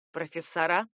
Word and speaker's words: профессора: Правильное ударение — именно профессора.